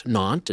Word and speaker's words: Nantes: Now, we pronounce it Nantes. It's spelled N-A-N-T-E-S.